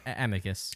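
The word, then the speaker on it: Amycus: Amicus.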